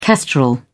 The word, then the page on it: kestrel: British English